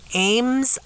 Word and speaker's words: Ames: I have a question here that was sent to me from Andy in Ames, Iowa. I think it's pronounced Ames, A-M-E-S, Ames, Iowa.